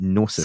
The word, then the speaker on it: Gnosis: Is it G-N-O-S-I-S? I ask this all the time. Gnosis? – Gnosis? – Gnosis. – Gnosis. Gnosis. I just can't pronounce it. Gnosis. – Silent G.